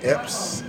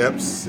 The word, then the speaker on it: Epps: Epps on Instagram, and that's TEPPS. And I'm on Facebook, Terique Epps, and my first name is spelled TERIQUE, last name EPPS.